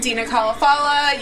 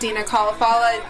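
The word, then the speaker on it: Dena Khalafallah: And Dina Kalafala. You can find me on Instagram and Twitter at Dina Kalafala, which is spelled DenaKhalafallah.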